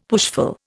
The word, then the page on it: pushful: Indian